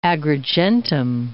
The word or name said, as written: Agrigentum